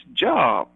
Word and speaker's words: Job: And he looked at it, he said, J-O-B? That's not Job, that's Job. And I began to explain to him, well, it's spelled J-O-B, but it's pronounced, this is a man's name, Old Testament biblical character, and his name was called Job, not like the J-O-B that we have to go to on Monday morning.